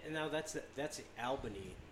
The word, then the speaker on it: Albany: Did I pronounce it right? No, you didn't. – No. – It's Albany. – That's right. If you say it wrong, they'll throw you out of town.